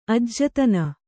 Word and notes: ಅಜ್ಜತನ: ajjatana

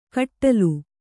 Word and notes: ಖಟ್ಲೆ: khaṭle

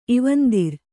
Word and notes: ಇವಂದಿರ್: kannada

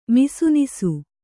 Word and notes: ಮಿಸುನಿಸು: Kannada

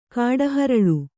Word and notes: ಕಿಡಿಹೊರಳಿ: kiḍihoraḷi

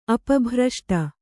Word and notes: ಅಪಭ್ರಷ್ಟ: Kannada

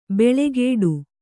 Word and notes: ಬೆಳೆಗೇಡು: beḷegēḍu

